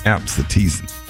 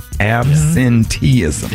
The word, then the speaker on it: absenteeism: I had to break that thing down in syllables. – Absenteeism. That word was absent from your vocabulary. – That's five syllables, I think.